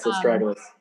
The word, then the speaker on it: astragalus: And the list includes, never even heard of this one, but astragalus. Is that how you pronounce it? – Yes, astragalus.